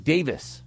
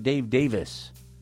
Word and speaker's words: Davies: It sounds like Davis. I heard an interview with him, and I'm like, the interviewer is saying his name wrong. But someone's like, no, he pronounces it Dave Davis. Kind of like Maurice Gibb. Someone's, you know, it's an enunciation thing. Just because I want to Americanize everything.